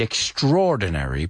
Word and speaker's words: extraordinary: Pronounce it with me. It's not an easy one. Extraordinary. Extraordinary. Extraordinary. It's not extraordinary. It's extraordinary. It's weird because we mix those two vowel sounds, the A and the O.